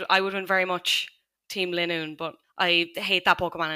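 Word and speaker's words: Linoone: I did have to look up how to pronounce Linoon. It seems like consensus is it's Linoon.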